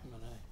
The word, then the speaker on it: Rimini: In Italy, they pronounce it Rimini, I think, but in Montana, you say Rimini.